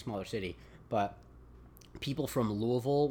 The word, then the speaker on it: Louisville: It's Louisville. – Louisville. – I like to always try to pronounce things the way people, locals do because, you know, it's a vestige of when I was younger and all I cared about was looking smarter than everyone else.